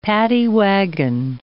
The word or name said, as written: paddy wagon